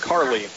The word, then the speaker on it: Carle: So the last name is spelled C-A-R-L-E, but it's pronounced Carley.